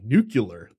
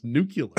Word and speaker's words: nuclear: Rick Perry would be the guy that does the Homer Simpson line, like nuclear. The word is pronounced nuclear.